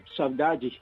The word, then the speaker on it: saudade: En portugués se pronuncia saudade. Saudade.